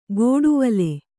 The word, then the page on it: ಗೋಡುವಲೆ: gōḍuvale; noun